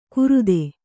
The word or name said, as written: ಕುರುದೆ